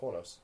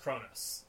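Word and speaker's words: Qo'noS: Sorry, Q-O-apostrophe-N-O-S, Quonos. – But it's pronounced Kronos. It's spelled... – That's because it used to be spelled K-R-O-N-O-S.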